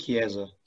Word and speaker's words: Chiesa: I think Chiesa.